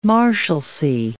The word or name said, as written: marshalcy